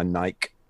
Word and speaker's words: Nike: Funnily enough, um, as an ancient Greek scholar, as I am, or at least was in my university days, the correct pronunciation is actually Nike, because that's the name of the goddess of victory.